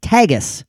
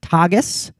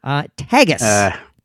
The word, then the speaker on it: Tagus: Now, it is, just to be clear, it is pronounced Tagus, not Tagus, not Tagus. Tagus. T-A-G-U-S.